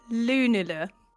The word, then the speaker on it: Lunula: Ilaria Lunula.